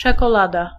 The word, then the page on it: czekolada: /t͡ʂɛ.kɔ.'la.da/